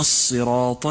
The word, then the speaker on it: الصراط: أما الملاحظة الثالثة فأقول لك أيها الأخ، إياك أن يختلط نطق حرف الصاد من قولك الصراط بحرف السين من قولك المستقيم، فالصاد حرف مفخم والسين حرف مرقق لطيف يحتاج منك إلى انتباه وعناية. اقرأ هكذا اهدنا الصراط المستقيم ص و س، فلا تخلط بينهما.